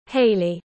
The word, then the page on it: hailey: British English